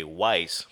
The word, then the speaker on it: Weisse: Now, you're looking at the can and you see the term Weiss, but it's spelled W-E-I-S-S-E. One would say Weiss, but it's actually like V, Weiss.